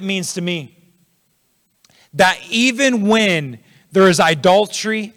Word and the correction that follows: idolatry: I say that wrong, idolatry in the land, there's another syllable in there, isn't there?